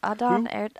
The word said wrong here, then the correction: Adar: Adar, whatever, the father of the orcs. – Adar. – Adar. – Yes. Adar.